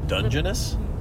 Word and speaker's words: Dungeness: I'm going to get a couple of crabs to put in there, but I will just go buy Dungeness crabs from the... – Dungeness? – From the meat market. – Is that how you say it? – Mm-hmm. – I didn't know that.